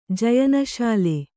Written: ಜಯನಶಾಲೆ

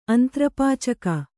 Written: ಅಂತ್ರಪಾಚಕ

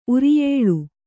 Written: ಉರಿಯೇಳು

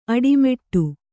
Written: ಅಡಿಮುಡಿ

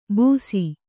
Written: ಬೂಸಿ

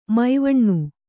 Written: ಮಾವಣ್ಣು

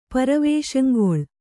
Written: ಪರವೇಷಂಗೊಳ್

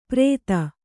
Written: ಪರೇತ

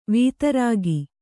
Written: ವಿದಾರಕ

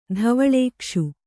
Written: ಧವಳೇಕ್ಷು

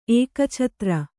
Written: ಏಕಛತ್ರ

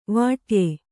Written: ವಿಡಾಯ

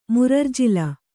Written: ಮುರರ್ಜಿಲ

